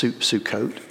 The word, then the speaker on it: Sukkot: Oftentimes we say this one as Succoth, that's Sukkot, would be the Hebrew pronunciation, S-U-C-C-O-T-H.